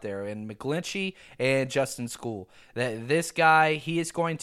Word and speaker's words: Skule: All right, the second prospect that we're going to be talking about today is Justin School, which he's got the coolest spelling ever, S-K-U-L-E, and it's pronounced school, like kids go to school.